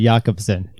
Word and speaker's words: Jacobson: Fabulous Fabio Jacobson. Soft J. Oh, sorry, Jacobson.